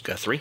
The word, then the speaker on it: Guthrie: He grew up in Kentucky, where he ended up taking over the family farm in a place called, I think, Guthrie is how it would be pronounced. – Guthrie, yeah.